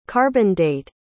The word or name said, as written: carbon-date